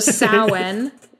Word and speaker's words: Samhain: It looks like it's pronounced Samhain, but I've been told multiple times that's wrong. So Samhain, which people call Halloween.